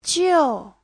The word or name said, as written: jiu4